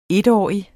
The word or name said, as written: etårig